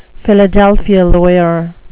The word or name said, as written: philadelphia lawyer